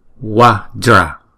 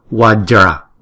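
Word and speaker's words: Vajra: Chữ kế tiếp là chữ Watra. Chữ này có hai âm Wa và Dra. Wajra. Chữ J này chúng ta đọc là J, J. Wajra, Wajra.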